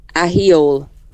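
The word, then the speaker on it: Ahiohill: Okay, if you give up, it's called Ahiol. – What? – I'll say that again. – Yeah. – Ahiol. Yeah, not spelt at all. It's pronounced. – Ahiol.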